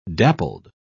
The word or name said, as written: dappled